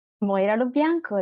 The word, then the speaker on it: Moira Lo Bianco: Moira Lo Bianco.